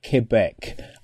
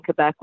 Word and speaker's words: Quebec: Quebec. I think I've said it. I said you've got to say it with a K, not with a Q.